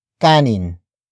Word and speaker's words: kanin: Kanin is spelled as K-A-N-I-N.